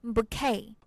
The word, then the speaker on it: bouquet: 哎，所以呢，Peter 老师给了我一个建议，说，那你这是要想结婚的话，你赶紧待会儿要去接新娘的这个捧花啊，这花束一个捧花叫做 bouquet。 – 我们拼一下这个单词 b o u q u e t，注意一下后面这个 t 呢是不发音的。